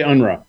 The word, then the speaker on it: Unrau: Chris, how do I pronounce your last name? So don't butcher it, bud. – We would say Unrau.